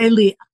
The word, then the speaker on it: Eli: Now this was, it was in Eli or LA, how do you say it? Eli. I pronounce it Eli.